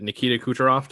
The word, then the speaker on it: Nikita Kucherov: I, I really liked uh, is it Nikita Kucherov? Did I get the name right? – Yes. Uh, yep.